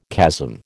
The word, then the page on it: chasm: American English